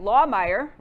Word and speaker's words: Lahmeyer: So, Pastor, I'm going to say it right, Lahmeyer, right? – You got it.